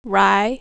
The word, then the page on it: rye: American English